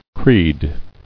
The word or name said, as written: Creed